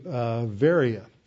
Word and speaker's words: Veria: and to Veria. That's how the Greeks pronounce this.